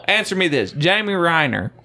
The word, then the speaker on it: Renner: How do you say his name? Reiner or Renner? – Renner.